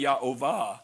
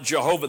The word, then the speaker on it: Yahweh: That's one of the reasons I believe that most likely the correct pronunciation is Yahweh. It may be Yahovah, not Jehovah. There's no J in Hebrew, but I tend to believe it's Yahweh.